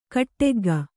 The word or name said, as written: ಕಾಡಕಾಗೆ